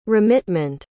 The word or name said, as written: remitment